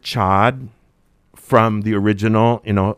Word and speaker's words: chaud: Because isn't there a French word, chaud, it means hot? – Chaud. – Chaud, okay. – The French pronounced chaud. – But it's spelled C-H-A-U-D? – C-H-A-U-D, yes. And if it's a feminine, then it's chaud. – Chaud. – And then you pronounce the D because there's an E that comes after.